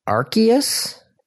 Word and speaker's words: Arceus: Dave has typed to me Arceus. – See, that's how I pronounce it too. And I believe that is the official pronunciation. But I know a lot of people who pronounce it Arceus.